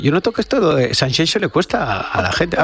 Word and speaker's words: Sanxenxo: Lo pronuncias. Sanxenxo, ¿eh? – Es como Shakira.